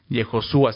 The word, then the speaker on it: Yehoshua: De repente, al traerlo al hebreo y tratarlo de poner en un idioma que podamos hablarlo, le ponen muchos, ¿no?, nombres, pero es Yehoshua, se escribe así con H, ¿no?, en medio, Yehoshua, que significa el Señor es salvación, o el Señor salva, ¿no?